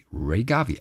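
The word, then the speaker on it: Rea Garvey: Ray, Ray, Ray Garvey, R-E-A. R-E-A, ja, Ray. Ray, okay. R-E-A. – Es gibt auch RAE vielleicht, aber wer wird denn RAE? die wird auch Ray ausgesprochen.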